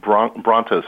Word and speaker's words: Bronto: Can you spell that? How do you spell that? Broncobytes? What's the spelling? – I believe it's Bronto, so B-R-O-N-T-O, byte.